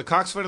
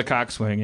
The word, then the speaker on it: coxswain: It's spelled coxswain. Coxswain, but it's coxswain. – Coxswain. – Like boatswain is bosun. A gunsel is a gunnel or whatever. You know, they shorten things.